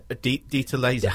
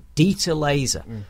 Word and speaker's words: Dieter Laser: Zeta. – Dieter Laser. – I always get his name wrong. Dieter Laser.